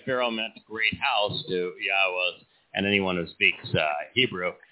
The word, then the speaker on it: Yahweh: Yahweh. The proper pronunciation of Yod-Heh-Wah-Heh, our Eloah as God. He directed us to pronounce it this way based upon his Torah teaching regarding his Haya existence and our Shalom.